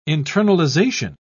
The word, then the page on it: internalisation: British English